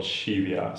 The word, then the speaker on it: Scivias: so the name of the video series is called Scivias.